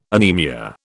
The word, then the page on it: anaemia: British English